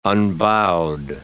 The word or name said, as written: unbowed